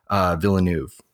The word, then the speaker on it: Villeneuve: And it's like, I love Villeneuve. That's his name, right? That's how you pronounce it. Yep.